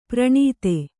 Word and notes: ಪ್ರಣೀತೆ: praṇīte

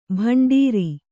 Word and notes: ಭಂಡಾರಿ: bhaṇḍāri

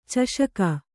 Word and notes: ಚಷಕ: caṣaka; Kannada

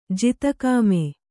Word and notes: ಜಿತಕಾಮೆ: jita kāme

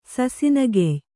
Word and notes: ಸಸಿನೆಗೆಯ್: sasinegey

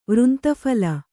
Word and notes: ವೃಂತಫಲ: kannada

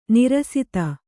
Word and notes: ನೀರಸತೆ: Kannada

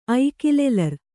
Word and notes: ಐಕಿಲೆಲರ್: Kannada

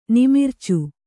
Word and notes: ನಿಮಿರ್ಚು: nimircu

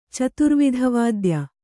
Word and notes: ಚತುರ್ವಿಧವಾದ್ಯ: caturvidha vādya

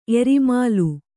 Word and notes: ಎರಿಮಾಲು: erimālu

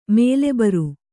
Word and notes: ಮೊಲೆಬರ್: mole bar

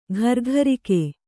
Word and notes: ಗುರಿಗಾರಿಕೆ: gurigāriki

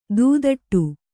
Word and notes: ದೂದಟ್ಟು: Kannada

